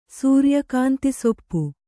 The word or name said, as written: ಸೂರ್ಯಕಾಂತಿಸೊಪ್ಪು